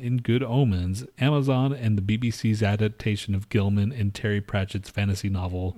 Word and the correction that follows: Gaiman: Gaiman. – Were you saying that just to be funny, or did I say it wrong? – That's his name, Neil Gaiman.